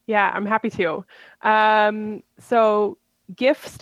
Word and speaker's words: GIF: Now, talking about things coming up, we're excited for another year of, we like to say gajif, because is it a hard G or a soft G? So we throw in both G's. We're not sure if it's GIF or GIF. – GIF, it's GIF.